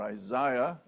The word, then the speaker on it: Isaiah: So turn in your Bibles to Isaiah, or Isaiah, as the Americans quaintly say.